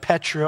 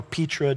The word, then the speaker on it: Petra: There's a place called the fortress city of Petra or Petra, depending upon who's explaining you how to pronounce it.